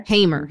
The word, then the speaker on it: Hamer: Am I saying it? Fannie Lou Hamer, right? – Hamer.